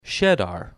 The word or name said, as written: Schedar